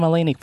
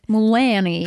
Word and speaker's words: Mulaney: That's how you say his name. – Why did you think you said it? – Mulaney. – Have you never heard anyone say it out loud? – I wasn't listening.